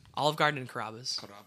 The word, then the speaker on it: Carrabba's: The B is doubled as well, so you have to also when consonants are doubled in Italian, they're meant to be longer. So it's like Carrabba's.